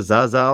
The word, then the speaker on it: Zaza: Das ist Simone Sasa. Muss man sagen, also mit Z geschrieben, vielleicht wieder Zaza ausgesprochen oder so immer.